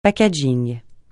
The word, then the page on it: packaging: francais